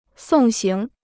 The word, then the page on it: 送行: sòng xíng